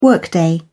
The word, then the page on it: workday: British English